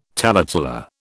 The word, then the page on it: taleteller: British English